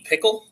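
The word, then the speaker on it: Pikiell: Rutgers coach Steve Pikel. – Rhymes with Michael.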